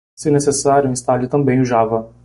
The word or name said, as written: Java